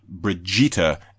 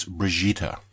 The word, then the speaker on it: Brigitte: It's not Brigitte, it's Brigitte. With a soft what? G. – Oh, like Bruschetta. No, come on. – Brigitte.